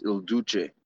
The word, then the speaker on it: Il Duce: I hate to correct you so early in the show, John, but it's pronounced Il Duce.